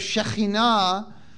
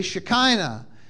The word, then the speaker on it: Shekhinah: But in English, people, instead of Shekhinah, they say Shekinah. Instead of Malchitzedek, my righteous king, they say Melchizedek. Because people don't know how to pronounce Hebrew, so they butcher it.